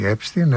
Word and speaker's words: Epstein: Εδώ στην Ελλάδα λένε Epstein, γιατί κανονικά ως γερμανικής προέλευσης προφέρεται Epstein, αλλά στις ΗΠΑ που τα προφέρουν εξαμερικανισμένα προφέρεται Epstein. Και εγώ βεβαίως, εφόσον ο ίδιος προσφωνούσε τον εαυτό του Epstein, χρησιμοποιώ το Epstein και όχι το Epstein.